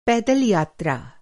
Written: पैदल यात्रा

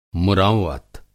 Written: मुरौअत

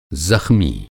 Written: ज़ख़्मी

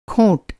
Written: खोंट

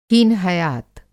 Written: हीनहयात

